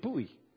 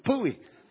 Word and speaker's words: pui: I said, well, how do you say that in Romania? She said, pui. I said, pui? She said, you know, like, pui.